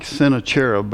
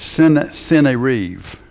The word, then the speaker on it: Sennacherib: And it starts out, "And after these acts of faithfulness," so referring back to all the good things he'd done, I know it looks like Sennacherib, but it's Sennacherib is the way that they would pronounce it, so you may hear it both ways from me.